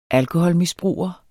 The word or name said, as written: alkoholmisbruger